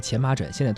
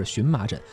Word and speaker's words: 荨麻疹: 比如说，呃，原来有人读这个呃前麻疹，现在读音是荨麻疹，包括是矮板还是呆板，是确凿还是确凿啊，呃，是说课还是说课等等，这些也都是改变之后，包括这文章中提到的一些词，网友们争议的焦点。